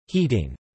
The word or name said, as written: heating